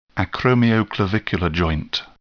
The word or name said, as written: acromioclavicular joint